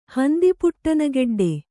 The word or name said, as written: ಹಂದಿಪುಟ್ಟನಗೆಡ್ಡೆ